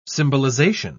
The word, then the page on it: symbolization: British English